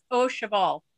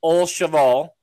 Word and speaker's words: Au Cheval: It's Au Cheval. – Au Cheval.